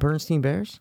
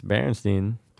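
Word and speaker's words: Berenstain: It's Berenstein. Like Jay Baruchel. – Look, it even. Yeah, it's spelled that way, but is it pronounced that way? – Oh, so it is spelled like B-E-R-E-N. – I think it's pronounced Berenstein Bears. But I understand why people would say Bernstein Bears.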